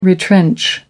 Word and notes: retrench: American English

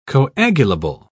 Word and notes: coagulable: British English

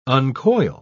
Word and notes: uncoil: British English

